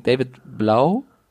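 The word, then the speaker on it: David Blough: Ähm, Driscoll hat nicht gespielt, sondern David, jetzt muss ich den Namen wieder sagen, David Blau. – Ja, so stand es im Pronunciation Guide.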